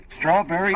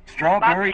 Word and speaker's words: Strawberry: Strawberry. – Bob what? – Strawberry. – Bob Calvary? – Strawberry. – Strawberry? – Yes. – Spell it. – S-T-R-A-W-B-E-R-R-Y.